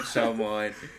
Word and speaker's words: Shyamalan: Night Shyamalan. – Hey, it is M. Night Shyamalama Ding Dong. – M. Night Shyamalama Ding Dong. – Whenever you can't say his name, just think Shyamalama Ding Dong.